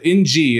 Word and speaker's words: Ng: Jeff with a G said, when you see the Asian last name of NG, you pronounce it as Ng, as if there were an I there.